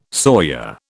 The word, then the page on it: sawyer: British English